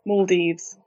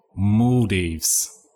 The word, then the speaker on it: Maldives: Maldives. – Maldives. – Yeah. – Like between an A and an O, Maldives.